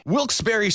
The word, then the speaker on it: Wilkes-Barre: Wilkes-Barre Scranton's 97.9X.